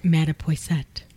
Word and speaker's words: Mattapoisett: In Mattapoisett. See, I said it right again.